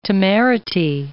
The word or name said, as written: temerity